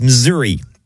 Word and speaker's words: Missouri: That's how former NWA president Bob Geigel pronounced it, but it's pronounced Missouri.